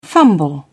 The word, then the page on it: fumble: British English